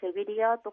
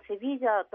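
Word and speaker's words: Sevilla: 日本の地図などの表記ですとセビリアと書かれていることが多いかと思うんですけれども、こちらの方はご自身の町をセビージャと発音されることが多いです。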